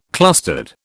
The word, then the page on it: clustered: British English